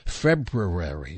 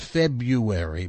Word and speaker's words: February: This is the fourth of the hardest day of the month to pronounce correctly. February, not February, but, and why, when they established the months, they didn't just go January, February.